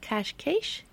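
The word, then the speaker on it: Cascais: I found out you told me it's pronounced cash cache. – The S. – Is SH. – Yeah, it's like a sh sound. – Cash cache.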